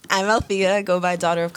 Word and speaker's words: Althea: Althea. – So it's not. – No. – I try to throw it to her. – No, like, what we did there was pronounce the L that you missed.